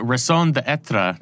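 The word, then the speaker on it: raison d'être: the raison d'etre. Am I pronouncing that correctly? Close enough for me.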